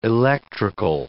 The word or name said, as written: electrical